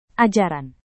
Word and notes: ajaran: indonesian

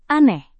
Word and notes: aneh: indonesian

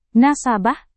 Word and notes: nasabah: Indonesian

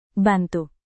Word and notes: bantu: indonesian

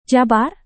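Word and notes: jabar: indonesian

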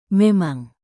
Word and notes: memang: Indonesian

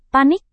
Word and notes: panik: Indonesian

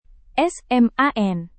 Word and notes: sman: Indonesian

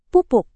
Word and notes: pupuk: Indonesian

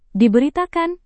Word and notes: diberitakan: Indonesian

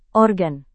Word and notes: organ: Indonesian

